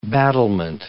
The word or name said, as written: battlement